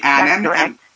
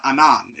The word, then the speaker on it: Annan: Kofi Annan. And I read a fantastic little clip that your uncle stated that the last name rhymes with Cannon, so it's Annan and not Anan.